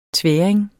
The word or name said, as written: tværing